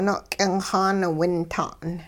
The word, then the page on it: no'k'ingxaan-winta'n: hupa